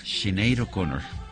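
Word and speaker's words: Sinéad O'Connor: Sinead O'Connor es lo que, ¿sí?, lo que me dicen aquí las... – Bueno. – Los verificadores de pronunciación. – Pues ahí está. – Sinead O'Connor.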